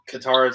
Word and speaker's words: Katara: They get Katara right. Katara is correct, but then they say Sokka instead of Sokka, which is what it's supposed to be.